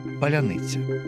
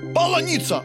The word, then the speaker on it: паляниця: Та загарбники, хоч як прикидалися і старалися, не могли вимовити те паляниця. – Паланіця! Полуніця!